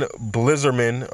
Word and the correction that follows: Bilzerian: or Dad Blizzalarian. How do you say the hell's name? Dan Bilzerian, the guy from Instagram that has all those fine women around him.